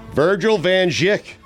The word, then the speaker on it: Virgil Van Dijk: Virgil Van Dijk. Clutch. VVD. Did I say it right? – You nailed it.